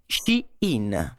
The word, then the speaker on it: Shein: Parliamo di Shane, o come si dovrebbe pronunciare, Shein piccolo recap su che cos'è il fast fashion.